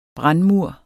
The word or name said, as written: brandmur